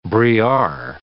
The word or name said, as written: briard